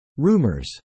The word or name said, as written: rumors